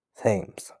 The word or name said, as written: Thames